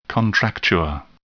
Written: contracture

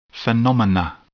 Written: phenomena

